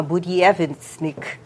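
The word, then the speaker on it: Buryevetsnik (Буревестник): Esse míssil se chama Buryevetsnik, mais ou menos isso é a pronúncia em russo.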